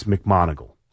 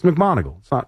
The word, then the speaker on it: McMonagle: And see if you could tell Gary to get it's McMonagle. I don't know if they've said it wrong, but every time they do, I get a million tweets. It's McMonagle. It's really not that hard. Just sound it out. It's as it's spelled, really. I mean, I don't understand it, but that's okay. – It is how it's spelled.